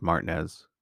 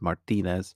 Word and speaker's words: Martinez: But it's pronounced Martinez there, but I know it as Martinez because that's how it's spelled, the last name.